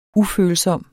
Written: ufølsom

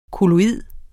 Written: kolloid

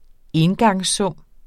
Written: engangssum

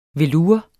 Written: velour

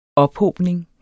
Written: ophobning

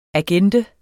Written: agende

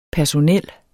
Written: personel

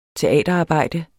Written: teaterarbejde

